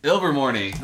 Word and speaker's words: Ilvermorny: Yeah, Ilvermorny. – Ilvermorny. Nice, dude. Fucking nailed it.